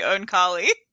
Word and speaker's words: Oankali: I yeah, no, that you you you keep going back and forth between two pronunciations. I think it's supposed to be own collie.